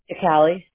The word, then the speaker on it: Cicale: So your first name, I want to pronounce it correctly, Karas, C-A-R-A-S, is that correct? – Yes. Uh-huh. – Okay, what's your last name, please? – Cicale, C-I-C-A-L-E. – C-I-C? – A-L-E. – A-L-E, okay.